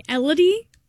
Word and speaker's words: Elodie: Yeah, I like Elodie, which is like melody with an E.